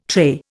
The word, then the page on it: tray: Indian